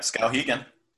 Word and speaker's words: Skowhegan: Uh, so Skowhegan, I don't know if I'm saying that right. Um, Marble Works, is that right? – Yep, Skowhegan.